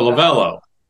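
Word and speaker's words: Lovullo: Are they still playing to go, LaVangie, is that how you say it? – No, Lovello. You're thinking of Dave Lavella, Torrey Lavella.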